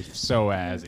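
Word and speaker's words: psoas: Soas. – Issue. Yeah, with a P like Pfizer. – Right. Exactly. – Yeah. – P like Pfizer. – That silent P up top.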